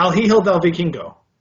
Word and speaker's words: El Hijo del Vikingo: Yeah, El Hijo del Vikingo.